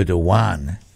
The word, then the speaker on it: Erdogan: Erdogan. – Yeah. Erdogan. – The names around that area with a G are usually pronounced with a Y. – Right. – Like Nick Kyrgios, not Kyrgios.